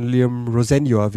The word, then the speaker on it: Liam Rosenior: Von Liam Rosenior, wie er ausgesprochen werden möchte.